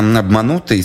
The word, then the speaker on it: Обманутый: Это песня «Обманутый» с ударением на букву «У».